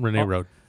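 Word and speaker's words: Rennay Road: Rene Road. – Rene Road, yep. – R-E-N-N-A-Y, DeForest Street, First Street.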